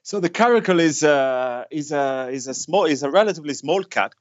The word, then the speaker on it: caracal: which is the caracal.